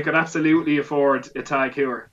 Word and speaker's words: Heuer: I don't think Tag Heuer is how you pronounce it. – Is it Tag Heuer or Tag Heuer? – It's Heuer, man.